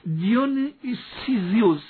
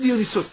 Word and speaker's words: دیونیسیوس: حتی این دیونیسوس هم اینجا عرض بکنم و در ترجمه دقیق انگلیسیش دیونیسیوس هست، آره، من چون سخت تلفظ میشه ما راحت دیونیسوس تلفظ میکنیم، اینم من عرض کرده باشم.